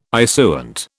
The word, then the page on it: issuant: British English